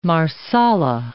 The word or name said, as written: marsala